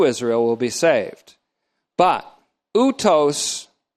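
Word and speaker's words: utos (οὕτως): Now, my first double take was that the word in this way, in this manner, utos, O-U-T-O-S, utos, or utos, that's a long O, utos.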